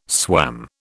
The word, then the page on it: swam: British English